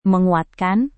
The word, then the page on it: menguatkan: Indonesian